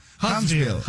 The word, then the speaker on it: Huntsville: Huntsville, City. ¿Sí lo dije bien, o no? – Sí, sí, sí. Huntsville.